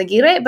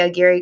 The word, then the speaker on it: Aguirre: It's Spanish, so it's Aguirre, but I just say Aguirre because I'm mostly for you white people.